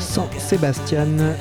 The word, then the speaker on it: Sans Sébastien: Suspendu à mon verre, je Sans Sébastien dans Yumi, sans Sébastien, sans ça s'écrit S-A-N-S.